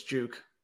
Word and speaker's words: JVKE: Some of these, like, I'm not even sure how you pronounce J-V-K-E. So that I'm going to go with it's Juke. – All right, let's do that. Maren Morris, Juke is on February 10th.